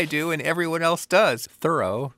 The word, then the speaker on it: Thoreau: Everything we know about how his name should be pronounced indicates that it should be Thoreau. It's the way it's always been pronounced in his hometown of Concord.